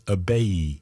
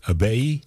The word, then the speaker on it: Ibeyi: And Lisa in France, known as Abeyi, that's spelled I-B-E-Y-I, Abeyi.